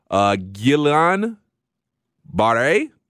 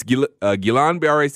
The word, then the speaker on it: Guillain-Barré: He has what is called Guillain-Barré. I think I said that right, but it is a syndrome. It's Guillain-Barré syndrome, and it's a rare neurological disorder.